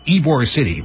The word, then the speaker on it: Ybor: wired cameras around Raymond James Stadium and in Tampa's nightlife, which I think is pronounced Ybor City, Y-B-O-R.